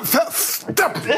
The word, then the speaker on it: Verstappen: Verstappen! Abdelkarim hat den Namen Verstappen richtig ausgesprochen. So müsste er eigentlich, so müsste er in der Heimatsprache.